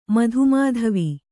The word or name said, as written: ಮಧುಮಾಧವಿ